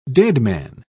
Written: deadman

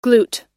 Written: glute

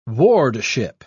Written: wardship

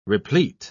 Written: replete

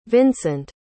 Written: vincent

